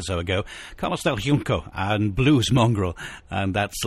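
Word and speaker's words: Carlos del Junco: He's from Cuba, and therefore it's in Spanish, but therefore probably not as Spanish would pronounce it, but more as the Cubans would pronounce it. Carlos del Yunco. Yunco.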